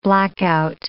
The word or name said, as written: blackout